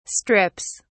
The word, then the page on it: strips: British English